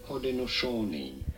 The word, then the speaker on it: Haudenosaunee: In multiple episodes, we reference the Hudasani peoples. – Oh, yes. – And that's not how you pronounce it. – Oh. – Or at least that's not how the people in my certificate online course are pronouncing it. – Okay, tell us. – Okay, well, now I forget, so give me a moment. – Haudenosaunee. – Oh. – Haudenosaunee. – Yes.